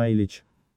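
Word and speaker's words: Ileach: Вне зависимости от того, как произносится название острова «Айла» или «Айлей», «Айлич», то есть «житель Айлы», произносится именно «Айлич».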